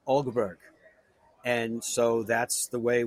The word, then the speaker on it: Åberg: Ågberg, like A-U-G? Ågberg? – It's almost like A-U-B-E-R-G, so Ågberg. It's like an O with a line over it. So it's like kind of soft sound coming in, but that's how you say it.